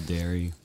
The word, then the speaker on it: Derry: I never went to Derry. – No, me either. – It rhymes with Barry.